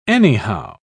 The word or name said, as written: anyhow